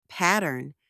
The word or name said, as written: pattern